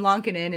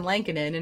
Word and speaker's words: Lankinen: Yeah, and then there's poor Kevin Lonkinen, who says his name Kevin Lonkinen, and everybody says Kevin Lankinen, and it's like, sir. – Yeah, Kevin Lonkinen.